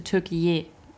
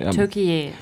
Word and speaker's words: Türkiye: Erdogan möchte jetzt nicht mehr Türkei, also ja, in Amerika und so, also international, sondern er möchte Türkiye, ausgesprochen heißt es. Also auf Türkisch heißt es ja, Türkei. – Türkiye.